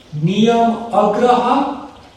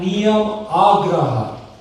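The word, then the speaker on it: niyamāgraha: Niyam agraha and niyam agraha. Same word, but one has a short A and one has a long A.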